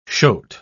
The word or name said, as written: shoat